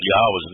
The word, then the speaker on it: Yahweh: you very quickly come to a conclusion that Yod-Heh-Wah-Heh is pronounced Yahweh, that there really isn't any way to refute that based upon the evidence that Yahweh has laid before us.